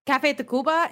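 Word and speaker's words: Café Tacvba: Café Tacuba. It's spelled T-A-C-V-B-A.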